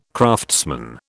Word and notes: craftsman: British English

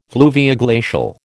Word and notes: fluvioglacial: American English

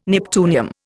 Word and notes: neptunium: Indian

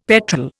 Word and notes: petrel: Indian

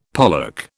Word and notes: pollock: British English

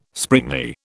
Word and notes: springy: British English

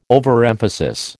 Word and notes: overemphasis: American English

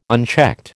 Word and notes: unchecked: American English

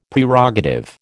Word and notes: Prerogative: American English

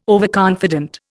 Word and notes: overconfident: Indian

